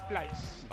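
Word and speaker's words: Pleiß: Yo diría que es Tibor Plais.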